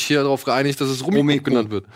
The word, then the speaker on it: Rummikub: Nein, warte mal kurz, die Aussprache ist tatsächlich Rummy Cup. Es ist ein englisches Spiel, beziehungsweise... – Natürlich heißt es Rummy Cup.